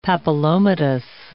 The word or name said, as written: papillomatous